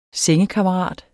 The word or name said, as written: sengekammerat